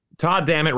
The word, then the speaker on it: Todd Dammit: Todd Dammit rhymes with God damn it, so that was kind of the nickname when I was younger.